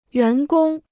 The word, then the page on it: 员工: yuán gōng